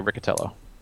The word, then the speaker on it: Riccitiello: Is that how you pronounce it for sure? – Yeah, yeah. I've heard many, many people say Riccitiello.